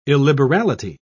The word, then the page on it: illiberality: British English